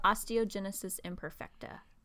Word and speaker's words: osteogenesis imperfecta: It's called osteogenesis imperfecta.